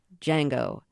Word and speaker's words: Django: And the kind of catchphrase, the trademark phrase from the movie, somebody asks him in his name, and he says, "Django, the D is silent."